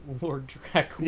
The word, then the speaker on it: Draculia: Lord Draculia of Transylvania walking around a Virgin megastore while Marilyn Manson sings. He is actually Lord Draculia. – Yeah, that extra syllable really fucked me up.